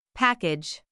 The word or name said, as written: package